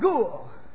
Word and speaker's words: gul: That word joy comes from an original Hebrew word pronounced gul, which means to spin around under the influence of a violent emotion.